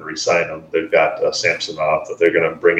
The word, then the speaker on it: Samsonov: Samsonov. You've got it with the Russians. It's always that middle syllable, you know.